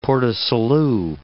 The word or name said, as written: Port du Salut